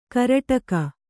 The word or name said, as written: ಕರಟಕ